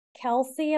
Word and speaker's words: Kelce: I'm with Ditz, and we're going to talk about Taylor Swift and Travis Kelce.